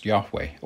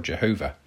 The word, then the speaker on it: Yahweh: "I am" is the Greek translation of the Hebrew word for the name of God, usually spelt YHWH, and pronounced Yahweh or Jehovah, although it's so holy that Jews don't pronounce it at all.